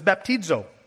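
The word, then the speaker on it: baptizo (βαπτίζω): So the Greek word for that is translated as baptize or baptism is baptizo.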